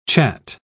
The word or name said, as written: chat